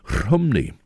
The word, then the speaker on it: Rhymney: His words were set to music by an American, Pete Seeger, and although mispronouncing the name of the Welsh town in the valleys, ironically, where Idris Davis himself was born, Rhymney, it should be, but it's not as Pete Seeger set it, and it was his setting which inspired a 1965 version by American band The Birds.